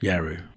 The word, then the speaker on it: Yawuru: Yawuru.